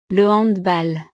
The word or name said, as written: le handball